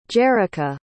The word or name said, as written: Jerrika